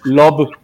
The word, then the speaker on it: Loab: Love, se pronuncia Love como L-O-B, pero se escribe L-O-A-B.